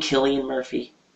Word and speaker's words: Cillian: You mean Killian Murphy? – Cillian. I thought he pronounced it as Cillian.